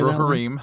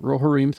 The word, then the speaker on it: Rohirrim: How do you say Rohirrim. – That? Rohirrim, thank you.